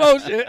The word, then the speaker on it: Ocean: I'm Ocean. I remember my friend that I grew up with, he used to always call me Ocean.